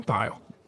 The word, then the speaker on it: Thile: Did I pronounce his name right? – Is that Kim? Kim Thile? – Thile. Okay. I just want to make sure I said his name right.